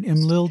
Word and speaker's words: Imlil: Imlil? Did I say that right? – That you've said it beautifully.